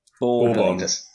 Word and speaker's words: Bourbon: Bourbon. – Okay. To answer the question... – The pronunciation in the English dictionary is Bourbon, not Bourbon, which is a whiskey liqueur.